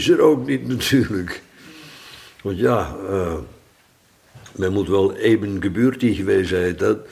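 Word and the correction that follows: ebenbürtig: In Duits zeg ik dat goed, zuster? Geboortig? – Ebenbeurtig. – Ebenbeurtig. Oh, ge hoeft er niet tussen. Ebenbeurtig, oké. Okay.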